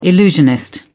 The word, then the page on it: illusionist: British English